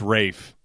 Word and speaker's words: Ralph: It's spelled Ralph, but it's Rafe.